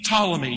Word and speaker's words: Ptolemy: Cassander, Lysimachus, Seleucus, P. Ptolemy. You don't pronounce it, but it's there written out.